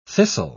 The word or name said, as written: thistle